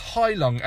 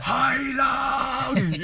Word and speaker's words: Heilung: So that's Heilung. And the album's called Lifa, or Lifa. It's spelled L-I-F-A, and Heilung is spelled H-E-I-L-U-N-G.